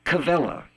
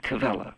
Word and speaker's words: Quelle: It's the word Kuelle. It's spelled Q-U-E-L-L-E, and in German, Q-U is pronounced like a K-V, Kuelle.